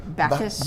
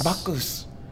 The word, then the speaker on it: Bacchus: It's called Bacchus. I don't know if I'm saying it right. Bacchus. – Bacchus. – B-A-C-C-H-U-S. You know, like the Greek god of wine.